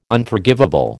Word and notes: Unforgivable: American English

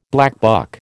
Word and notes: blackbuck: American English

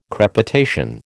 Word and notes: crepitation: American English

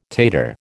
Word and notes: tater: American English

